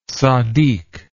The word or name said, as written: tzaddik